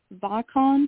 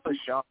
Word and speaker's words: Vachon: Luna Vachon? – Luna Vachon.